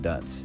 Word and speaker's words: Duns: His name was John Dunce, D-U-N-S. So if you pronounce his surname Dunce properly, you would find it sounds a lot like our word dunce, D-U-N-C-E.